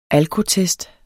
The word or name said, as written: alkotest